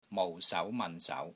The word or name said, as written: MOU4 SAU2 MAN4 SAU2